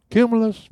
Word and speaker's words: cumulus: I'm looking out the window, and there's the luminous clouds, the big white one, fluffy ones? – Cumulus. Yeah. – Okay. I always said it wrong.